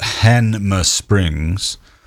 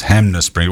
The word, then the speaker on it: Hanmer Springs: But anyway, it's spelt Hanmer Springs. But our propensity as humans, I think, is to put M before N anyway. But we all pronounce it as Hanmer Springs.